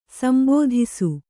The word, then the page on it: ಸಂಬೋಧಿಸು: sambōdhisu